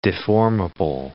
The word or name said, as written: deformable